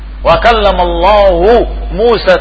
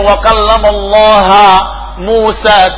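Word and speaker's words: wa kallamallahu Musa (وَكَلَّمَ اللَّهُ مُوسَى): wa kallamallahu Musa taklima. Mereka ganti, mereka rubah, mereka membacanya dengan nasb wa alamatu nasbihi fathah. Mereka membacanya wa kallamallaha Musa taklima, mengajak bicara Allah Musa.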